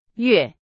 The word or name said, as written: 月